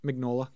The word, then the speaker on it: Mignola: Mike, how do you say his last name? – Mignola, yeah.